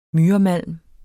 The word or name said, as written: MYREMALM